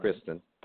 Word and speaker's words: Kristen: Um, I I I remember thinking like I had a bigger issue with uh, Kristen Stewart or Kirsten Stewart, uh, however you pronounce her name, Kristen.